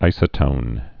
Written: isotones

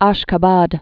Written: Ashkhabad

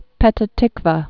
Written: Petah Tiqwa

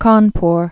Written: Cawnpore

